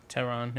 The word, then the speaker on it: Tehran: Is it pronounced Tehran? – Tehran, yeah.